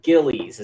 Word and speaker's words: Gillies: John Gills, the goalie. Let me see how you say his name. John Gillies. Is it Gills, Gillies? John Gillies is how you say his name.